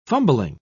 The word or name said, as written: fumbling